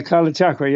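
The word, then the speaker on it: Kalachakra: Kala Chakra? – Yeah, Kala Chakra.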